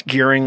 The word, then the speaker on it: Gearing: That's gearing like gearing up is what I always say.